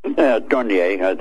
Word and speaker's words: Dornier: Second picture you'll see is of the Dornier, or Dornier. How do you pronounce it in German? – Dornier. I think it was a French name.